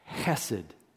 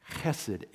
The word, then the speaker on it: chesed: It's a Hebrew word, chesed. Chesed. H, in English, we often spell it H-E-S-E-D, but the H is kind of a soft C-H, so it's like chesed.